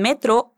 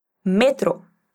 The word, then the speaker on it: metro: Y Celia quiere saber si es mejor ir en metro o en autobús. Dime cómo es metro en español. Metro. Cuidado, porque la sílaba más fuerte cambia en español.